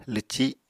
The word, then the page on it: lwci7: Apatani